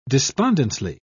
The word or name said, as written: despondently